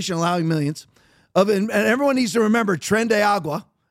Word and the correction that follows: Tren de Aragua: if I'm saying that right, Tren de Agua, I'm just going to pronounce it that way. It's Aragua, sort of, Tren de Aragua.